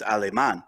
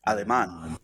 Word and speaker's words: Alemán: If you want to take it to the roots, it is a Spanish name. So the correct pronunciation is Alemán. Alemán. But Ale Man is, that works for me.